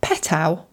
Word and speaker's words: Petow: The next question comes from Patricia Petow. Do you think I pronounced that correctly, Giles? – P-E-T-O-W, yeah.